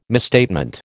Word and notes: misstatement: American English

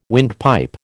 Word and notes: windpipe: American English

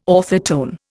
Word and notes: orthotone: Indian

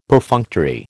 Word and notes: Perfunctory: American English

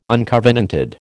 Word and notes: uncovenanted: American English

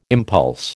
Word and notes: impulse: American English

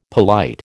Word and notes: polite: American English

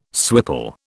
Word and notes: swipple: British English

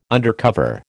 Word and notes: undercover: American English

